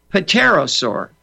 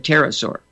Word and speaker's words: pterosaur: The book was about dinosaurs, and there was a dinosaur in there called the pterosaur, which is spelled P-T-E-R-O, pterosaur, with a silent P. So he read right through that word and read pterosaur, and I said, son, the P is silent. And the next time he read it, he said pterosaur.